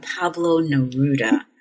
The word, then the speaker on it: Pablo Neruda: Pablo Neruda.